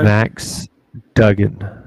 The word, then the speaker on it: Duggan: However, I really like, and I butchered his name as well, Duggins. Is that right? – Max Duggan.